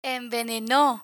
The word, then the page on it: enveneno: Spanish